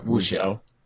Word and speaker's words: wuxia: Wu, wuxia movies. I don't say that right, do I? Uh, wuxia. – Sure, that's it.